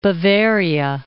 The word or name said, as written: Bavaria